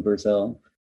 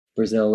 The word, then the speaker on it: Brasil: And I'm just my name on socials, Addison Brazil. Brazil, like the country, spelled with an S, as my dad would say, which is how they actually spell it in Brazil.